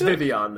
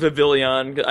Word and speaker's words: Vivillon: It's Vivian, though. Vivian. Yeah. It is a French pronunciation. – Yeah. At least we weren't saying Vivillon.